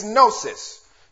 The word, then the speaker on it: gnosis: Knowledge in the Greek is gnosis. G-N-O-S-I-S. The G is silent. Gnosis, G-N-O-S-I-S, which in this context is general intelligence and understanding.